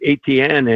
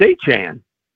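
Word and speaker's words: Etienne: And in his first press conference, you know, he says it's not ATN anymore. And I live not far away from a family of ATNs. They spell it the exact same way, said it that way. And he said, it's Achan.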